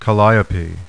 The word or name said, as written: CALLIOPE